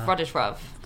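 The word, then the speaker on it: Władysław: I'm glad Anna pronounced his name at the start, which Starovich is not so hard, but how do you pronounce his first name? Something like... – Fortunately, it's my granddad's name, so I know it's Vladislav. – Vladislav. – I think that's probably incorrect. I'm sure a Polish listener can write in and correct me. – I think it's right, because it's got that L with a line through it, which is like pronounced like a W a little bit, isn't it?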